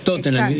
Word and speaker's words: Tottenham: En el caso de Tottenham y Twickenham, como terminan en enam, es clavado que no se pronuncia, no se aspira la H.